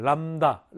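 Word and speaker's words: 람다 (λ): 그리스 문자야. 람다라고 읽어요. 람다. 람다.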